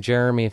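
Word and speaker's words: Jeremie: I'm on Facebook with the name Jeremy Faga.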